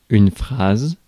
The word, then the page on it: phrase: /ˈfɹeɪz/; American English; [fɹeɪz]